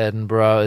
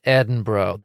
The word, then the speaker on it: Edinburgh: What is the rule here in Edinburgh? That sometimes after the word Edin, that the G is silent and the U and R invisibly switch places and then the H turns into an A?